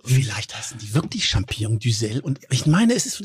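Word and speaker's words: Duxelles: Wie Düxel, so heißt es. Wie Düxel. – Also ich denke, es spricht sich wie Brüssel aus. Brüssel.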